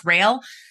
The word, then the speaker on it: Roehl: It's pronounced Roehl. It's German.